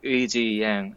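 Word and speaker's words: Yang Eui-ji: His name is really hard for American, like English speaker to pronounce. In Korean, it's Yang Eiji. I guess Eiji Yang. Yeah. Eiji part really gets people.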